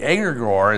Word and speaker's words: egregore: The egregore, it's spelled E-G-R-E-G-O-R-E, no relation to Al Gore.